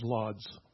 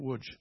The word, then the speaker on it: Łódź: Maybe you know it as Lodz, but it's Łódź. It's pronounced that way.